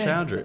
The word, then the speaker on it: Choudry: I'm here with Nooruddean and Choudry.